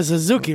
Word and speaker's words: Suzuki: You know, he is a Suzuki rider.